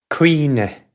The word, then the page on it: cuimhne: Irish